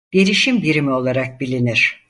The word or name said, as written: birimi